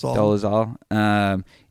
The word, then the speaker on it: Dolezal: even using Rachel Dolezal, how do you pronounce that? – Dolezal.